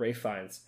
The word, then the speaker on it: Ralph Fiennes: And his name is Ralph Fiennes. And I spent a lot of time looking up how to say his name. So let my homework, you know, feed you. It's called Ralph Fiennes.